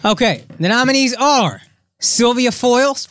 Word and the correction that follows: Fowles: Fowles? – Sylvia Fowles. Yes. Fowles. I'll help you with the pronunciation.